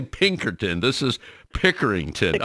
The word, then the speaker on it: Pickerington: it's Pickerington Public Library.